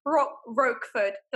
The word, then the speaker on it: Roquefort: Roquefort. Did I say it right? – Yes.